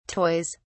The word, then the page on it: toys: British English